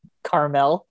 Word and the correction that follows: Carmichael: I'm saying his name wrong. Carmichael show. Why can't I pronounce that?